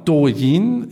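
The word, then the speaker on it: Douyin (抖音): Das letzte Unternehmen, was ich mitgebracht habe, ist Douyin. Jetzt korrigiere ich mich selber, weil ich gemerkt habe, dass es nicht richtig ausgesprochen wird. Das letzte Unternehmen ist Douyin.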